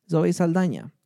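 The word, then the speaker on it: Saldaña: I don't know if her last name has that. Let me check so I can pronounce this right. Zoe Saldana. I don't think it has that little... It does. Zoe Saldana.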